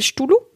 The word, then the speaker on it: Cthulhu: Est-ce qu'on prononce mal depuis le début Il s'appelle Ch'toulou Ch'toulou Ch'toulou C-T-H, t'as raison. C-T-H-U-L-H-U.